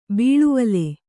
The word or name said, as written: ಬಾಳ್ವಲಿ